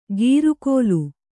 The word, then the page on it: ಗೀರುಕೋಲು: gīru kōlu